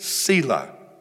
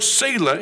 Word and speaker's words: Selah: The word Selah, now it's pronounced different ways. In the UK, it's pronounced Selah. So I'm going to pronounce it Selah. I think the ancestry goes back to the UK, so I'll pronounce it Selah. The other way it's pronounced is Selah. Selah. And then there's a third way, Selah.